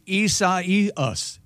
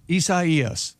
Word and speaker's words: Isaias: Just to make it's like Osiasis. – That's very, very close. It's Hurricane Isaias. Isaias.